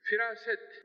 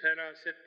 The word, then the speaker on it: firaset: Firaset, biz feraset diye söylüyoruz. Farsça tesiriyledir bu. İ'ler biraz E gibi okunur Farsça'da. Oradan geliyor.